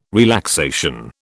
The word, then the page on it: relaxation: British English